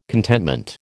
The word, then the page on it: contentment: American English